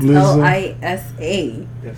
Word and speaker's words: Lisa: Her name's Liza Alexander. Lisa. Lisa, Liza, it's L I S A.